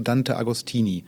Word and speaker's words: Dante Agostini: Ich hoffe, ich spreche den Namen richtig aus. Du bist nach Paris gefahren zu Dante Agostini. – Ja. – Spricht man ihn so aus? – Ja, ja.